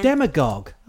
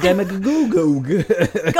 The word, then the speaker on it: demagogue: Demagogue. Oh, that's how you say it. I was saying it. Demagogogue.